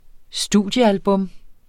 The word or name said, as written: studiealbum